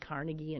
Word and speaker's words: Carnegie: You come to Pittsburgh and you find out you've been mispronouncing Carnegie all your life. And you don't say Carnegie Hall, you say Carnegie Hall, and that's what everybody in New York says too.